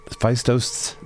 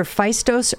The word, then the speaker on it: Phaistos: Phaistos disc? – Is either Phaistos or Phaistos. I've heard it pronounced both ways.